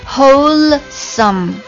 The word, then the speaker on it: wholesome: 这首歌里面你还会看到一个单词 wholesome， whole 啦啦啦，不要忘记了这个发音 wholesome，不要忘记哦，收尾的那个 wholesome，好，发标准就是 wholesome，